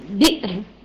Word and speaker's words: بئر: بير بس على نطق ايه؟ الهمزة بئر ماشي خلاص.